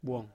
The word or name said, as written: buong